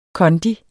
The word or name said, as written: kondi